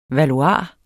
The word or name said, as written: valuar